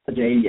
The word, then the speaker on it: Ajayi: Ajayi, yeah.